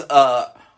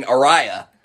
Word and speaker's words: Aria: Like, her name was Aria, and I was pronouncing Araya.